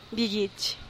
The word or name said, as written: bigich